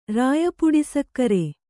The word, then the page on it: ರಾಯಪುಡಿಸಕ್ಕರೆ: Kannada